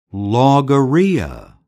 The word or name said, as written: logorrhea